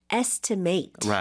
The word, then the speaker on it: estimate: estimate라고 right. – 하지만 여기서는 명사예요. 그래서 mm-hmm. estimate, matched the estimate. – estimate처럼 발음을 하셔야겠네요.